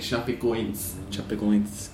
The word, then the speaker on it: Chapecoense: I watched a video on Globo to research out of the second leg, and the Brazilians were pronouncing it Chapecoense.